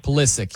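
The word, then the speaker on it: Pulisic: And they're going to look different with Polisky. Hopefully I said his name right. – Polisic. – That's the name, right? – Polisic.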